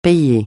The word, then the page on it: payer: francais